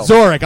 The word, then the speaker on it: Zorik: Let me just correct you on the pronunciation. I'm not a city, but I rhyme with a skull. I'll let you figure that out in your own time. – So it's Zorik. – Right, there you go.